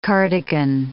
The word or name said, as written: cardigan